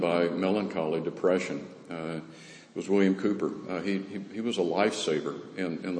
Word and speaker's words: Cowper: C-O-W-P-E-R, but it's pronounced Cooper.